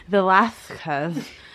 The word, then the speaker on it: Velázquez: Velázquez, or as the Habsburgs would have pronounced his name, Velázquez.